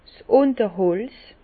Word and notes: 's Ünterholz: Bas Rhin; Herrlisheim

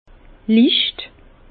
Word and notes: licht: Bas-Rhin; Herrlisheim

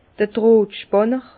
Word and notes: d'r Drohtspànner: Bas-Rhin; Herrlisheim

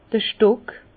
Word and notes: d'r Stock: Bas-Rhin; Herrlisheim

